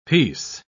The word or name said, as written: peace